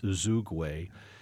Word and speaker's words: Uzoigwe: That's Uzoigwe.